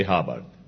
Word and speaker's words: Hubbard: Se escribe H-U-B-B-A-R-D, Hubbard.